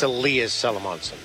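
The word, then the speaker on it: Elias Salomonsson: How do I say his first name? – Yes. – Elias. I wanted to say Elias Salomonson. – It's Elias Salomonson.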